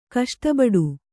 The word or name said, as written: ಕಷ್ಟಬಡು